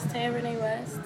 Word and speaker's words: Taia Renee West: My name is Taia Renee West. I know you probably can. – Yeah, you want to spell that? – Yeah, TaiaReneeWest. Like Kanye West, but not Kanye West.